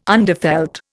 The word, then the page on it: underfelt: Indian